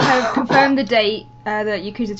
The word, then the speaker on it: Yakuza: So the first item we've got this week is something about Yakuza. What's that then? – You said it right. – I know how to say Yakuza.